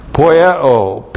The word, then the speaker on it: poieo: Poieo, P-O-I-E, long O, poieo.